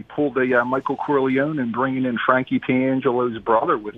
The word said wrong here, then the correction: Pentangeli: Except Frankie, how do you say the name again? – Pentangeli. – Yeah, Pentangeli's brother didn't speak.